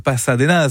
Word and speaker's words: Pasadenas: superbe ambiance mise par un groupe anglais, Pasadenas, sur votre radio locale ici au Serre. Oui, je prononce avec un petit peu d'espagnol quand même ce groupe anglais.